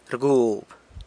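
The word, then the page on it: rgub: San Lucas Quiaviní Zapotec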